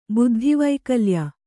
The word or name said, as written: ಬುದ್ಧಿವೈಕಲ್ಯ